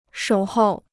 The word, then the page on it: 守候: shǒu hòu